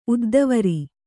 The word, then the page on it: ಉದ್ದವರಿ: uddavari; Kannada